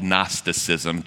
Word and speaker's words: Gnosticism: All over the Mediterranean, kind of the philosophy of the day was this thing called Gnosticism, G-N-O, not K-N-O. We flipped silent letters between our centuries.